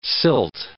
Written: silt

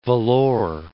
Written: Vellore

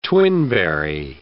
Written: twinberry

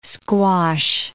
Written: squash

